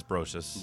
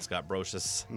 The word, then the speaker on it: Brosius: I think it's Brosius. – Brosius? – That's how I've always pronounced it, Scott Brosius.